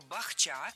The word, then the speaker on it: бахча: Но мы должны запомнить, что слово «бахча» всегда имеет ударное окончание.